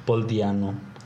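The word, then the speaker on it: Paul Di'Anno: Paul Diano.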